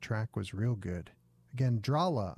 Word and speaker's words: Drahla: They're called Drahla, D-R-A-H-L-A. You pronounce that Drahla, right?